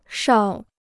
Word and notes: 哨: shào

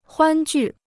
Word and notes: 欢聚: huān jù

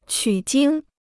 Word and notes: 取经: qǔ jīng

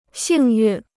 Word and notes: 幸运: xìng yùn

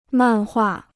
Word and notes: 漫画: màn huà

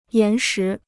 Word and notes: 岩石: yán shí

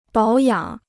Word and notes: 保养: bǎo yǎng

